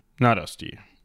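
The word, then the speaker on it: Nádasdy: Nadasti. Nadasti, ich würde sagen langes A, ja. Ja, mit diesem Pinöpel da oben drauf können wir das ja mal so aussprechen.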